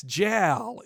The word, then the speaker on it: JAL: And I had to look up how to pronounce it because I was like, okay, well, I found a Janet Jackson commercial from 1990, and she says, yes, JAL in it for Japan Airlines.